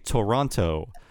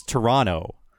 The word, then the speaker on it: Toronto: By the way, my wife, a Canadian, told me the dead giveaway that you're a foreigner is if you say Toronto. Mm. The correct way to say it, if you're Canadian, is Toronto. They kind of muffle that T into a D.